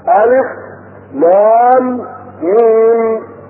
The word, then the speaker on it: الم: الشهر الثاني في افتتاح 29 سورة لهذه الحروف هو ان هذه الحروف تنطق مفردة وتنطق ساكنة هكذا. فمثلا حينما تقرأ الف لام ميم تقولها هكذا الف لام ميم وتقرأها بتأني وتقرأها ممدودة الف لام ميم ذلك الكتاب لا ريب فيه هدى للمتقين.